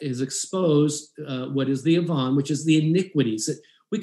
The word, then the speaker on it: avon: It's spelled Avon, A-V-O-N, but it's avon, which means iniquities.